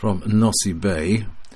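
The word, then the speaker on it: Nosy Be: DX News: members of the Italian D-Expedition team are active as 5R8TT and 5R8XX until Wednesday the 12th of November from Nossi Bay, until that's how it's pronounced, AF057 in Madagascar.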